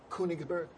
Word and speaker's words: Königsberg: Königsberg.